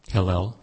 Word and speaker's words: Hillel: Hillel.